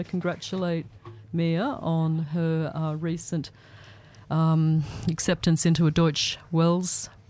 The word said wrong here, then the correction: Deutsche Welle: program in, or Deutsche Welle. I think that's the right way to say it, isn't Mia? – Deutsche Welle.